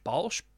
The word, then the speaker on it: Balch: Matthew, B-A-L-C-H. Balch? That's how I'd pronounce it. – Yeah, Balch. – Get in touch with him. As in like a Bolshevik. – Yeah, or like Grolsch, the beer.